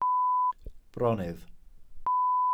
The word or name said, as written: bronnydd